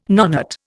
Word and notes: nonet: Indian

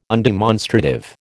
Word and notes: undemonstrative: American English

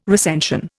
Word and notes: recension: Indian

